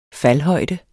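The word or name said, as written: faldhøjden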